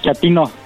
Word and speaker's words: Chatino: Chatino. ¿Chapino? No, chatino. – Es como chato, ¿verdad? Chatino. – Sí, así como chato, chatino.